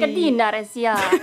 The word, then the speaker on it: Cadena SER: Cadena SER. Law would be... – Cadena SER.